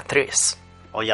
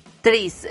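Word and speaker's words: Trees: Y hoy nuestro compañero Víctor nos trae también una serie de cómics llamada Tris. – Hoy hablamos de Tris. – Tris, referiéndose a árboles, no a Trish de persona, porque no sé por qué cuando siempre que lo pronunciáis creo que decís Trish. – Sí, sí, cuando me lo dijo él para apuntarlo dije, vale, T-R-I-S.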